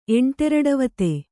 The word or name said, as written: ಎಂಟೆರಡವತೆ